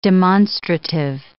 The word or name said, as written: demonstrative